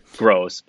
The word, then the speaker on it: Groose: We both went to Matt Groose, which is confusing to me because it looks like it'd be pronounced Groose, but it is Gross, right?